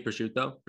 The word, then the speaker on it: prosciutto: Burrata y prosciutto.